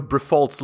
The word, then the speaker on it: Briffault: Because I don't know if you know Brifault's law. It's spelled B-R-I-F-F-A-U-L-T, Brifault.